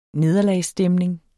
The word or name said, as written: nederlagsstemning